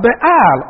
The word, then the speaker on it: Baal: So here they forsook him, the Lord, and served Baal, or Baal as some pronounce it, and the Ashtoreth, the idols.